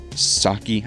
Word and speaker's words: Psaki: I cannot stand Jen Psaki, I guess is how it's pronounced. Just take the P out.